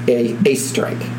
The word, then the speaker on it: Oesterreich: Now, it's not spelled this way. That's how it's pronounced. It's spelled O-E-S-T-E-R-R-E-I-C-H. – That's horrible. – But it's pronounced A-Strike. It's German.